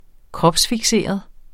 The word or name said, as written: kropsfikseret